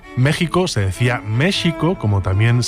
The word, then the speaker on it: México: Entonces, si México y Texas se pronuncian así, ¿por qué se suelen escribir con X?